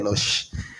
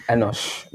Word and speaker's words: Enosh: How do you say it? – Enosh. – Enosh.